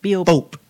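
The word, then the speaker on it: Bopp: Bope. What is it? – Bope. – Thank you. B-O-P-P. I was saying it wrong.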